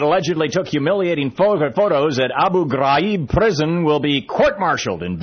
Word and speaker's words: Abu Ghraib: That's a tough one to pronounce if you want to say it the way that they actually say it. Abu Ghraib.